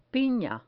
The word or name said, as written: pina